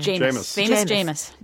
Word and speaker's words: Jameis: Jameis.